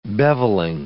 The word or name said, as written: bevelling